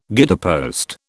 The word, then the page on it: guidepost: British English